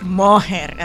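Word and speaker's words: Mójer: Mojer, así, con acento en la O.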